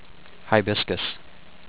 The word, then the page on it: Hibiscus: hi-BIS-cuss